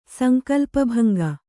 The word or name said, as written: ಸಂಕಲ್ಪಭಂಗ